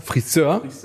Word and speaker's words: Friseur: Sagt er entweder, er geht zum Friseur, wo die 2. Silbe betont wird, ne? Friseur.